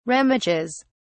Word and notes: remiges: British English

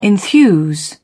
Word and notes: enthuse: British English

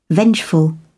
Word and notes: vengeful: British English

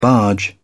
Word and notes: barge in: British English

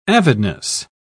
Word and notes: avidness: British English

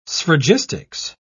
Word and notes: sphragistics: British English

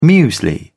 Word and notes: muesli: British English

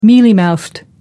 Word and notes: mealy mouthed: American English

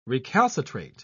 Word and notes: recalcitrate: British English

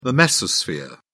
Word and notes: mesosphere: British English

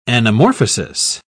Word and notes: anamorphosis: British English

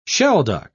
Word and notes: shelduck: British English

